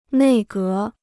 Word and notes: 内阁: nèi gé

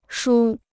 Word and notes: 梳: shū

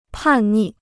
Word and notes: 叛逆: pàn nì

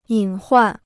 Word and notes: 隐患: yǐn huàn

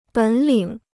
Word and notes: 本领: běn lǐng

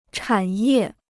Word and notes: 产业: chǎn yè

